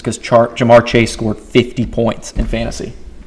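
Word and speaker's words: Ja'Marr Chase: Yeah, Jamar Chase, T.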